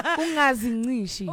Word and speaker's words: ungazincishi: Ungangcishi on. Did I say that right? – No, yeah, yeah, words. Yeah, yeah. You always, every time. – I said, what? – Ungazincishi.